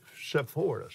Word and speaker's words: Sepphoris: What about the city of Sepphoris? Is that the way you pronounce that? – Yeah, Greek city.